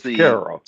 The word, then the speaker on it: Caro: Is it Caro or Cairo? – Caro, like Caro syrup, yes.